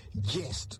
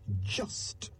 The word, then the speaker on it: just: J-U-S-T. How do you pronounce that word? Do you say just, or do you say jest, like I have been saying since I began? The correct pronunciation is just, just. To understand the word just, we must consider its origins. The Latin word jus, J-U-S, which means lawful, morally upright, legally right.